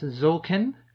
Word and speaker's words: Tzolk'in: So that's Zolkin.